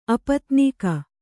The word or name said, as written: ಅಪತ್ನೀಕ